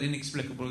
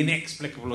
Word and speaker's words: inexplicable: can you explain to your continuity announcers that the stress on the word inexplicable is on the second syllable? I don't even know which one. I think it's meant to be inexplicable or something.